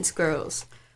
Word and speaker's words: squirrel: Can you phone in squirrels? I guess it's American, so squirrels. – Squirrel, where it's one sort of syllable.